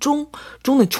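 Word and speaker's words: 쫑은 (钟): 그리고 벽시계는 쫑은 중점 그 중이라서요.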